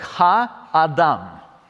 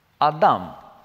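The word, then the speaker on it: Ха-Адам (הָאָדָם): И здесь интересное, как читается человек? Ха-Адам. Адам. Понимаете, да? Вот это Алеф, Далет, Мейм, три буквы. Вот. Они читаются как Адам.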